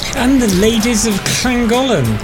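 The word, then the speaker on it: Llangollen: Llagollen.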